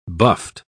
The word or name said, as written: buffed